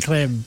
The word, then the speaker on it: creme: Because you look, they're not, it's spelled C-R-E-M-E, not C-R-E-A-M. – It's creme. – So it should be said with an accent. Creme.